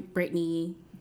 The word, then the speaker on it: Bryttnee: I'm Brittany, BRYTT.